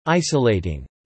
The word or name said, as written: isolating